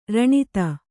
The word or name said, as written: ರಣಿತ